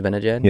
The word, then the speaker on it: Zibanejad: Oh, is it Zibanejad? – Yeah.